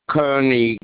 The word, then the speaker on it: Kearney: First name is David, DABID, Kearney, KEARNEY. But the policy is under my wife, but I'm on the policy too. – Okay, once again, can you spell out your last name again? – KEARNEY.